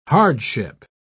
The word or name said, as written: hardship